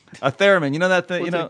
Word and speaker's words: theremin: We need a theremin. – A what? – Podcast of Doom. Or theremin. How do you pronounce it? – A theremin.